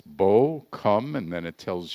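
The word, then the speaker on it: bo (בוא): It's the letters B and then O, which is pronounced bo.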